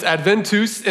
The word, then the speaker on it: Adventus: I mispronounced it last week. So I apologize for any Latin scholars if you were offended by my pronouncement. It's Adventus, and it means arrival or coming.